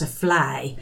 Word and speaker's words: vlaai: Lindbergh's tart has won some kind of a recognition as being a thing of national cultural heritage, the Limburgse vlaai, which is spelled V-L-A-A-I, but sounds like fly to you and me.